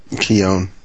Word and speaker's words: Keown: Flashback sequences by Dale Keown. And is that how you say it? Keown? – Keown. – Keown. Keown. – That's Keown. What I always said, yeah. – Keown, that would be right.